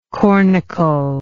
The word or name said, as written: cornicle